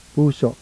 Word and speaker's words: puso: Now, illustrating the penultimate and glottal stress, please pronounce the Tagalog word for heart. – Puso.